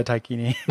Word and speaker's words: Daikini: Now I don't want to be a Taikini. – Is that Is that like Bikini with the D, or how do you spell that? – D A I K I N I.